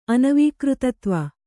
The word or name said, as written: ಅನವೀಕೃತತ್ವ